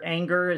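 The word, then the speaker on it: Ehinger: You know, they traded Charvarius Ward just ahead of the last preseason game for Parker Anger, is how you pronounce it.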